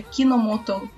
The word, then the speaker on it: Kinomoto: Sakura Card Captors conta a história da jovem Sakura Kimonoto. Eu nunca sei se é assim que se pronuncia. Kinomoto, o, o nome dela.